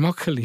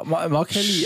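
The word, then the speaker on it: Makkelie: heißt der Makeli, ich habe es richtig ausgesprochen. Der darf doch jedes Finalspiel pfeifen. Das ist ja der Vorzeigeschiri momentan von der UEFA. Komplett verpfiffen, keine Ahnung wie. – Ja, Makeli, also ich hätte ihn schon auch so ausgesprochen. – Ja, man ist mit 2K geschrieben.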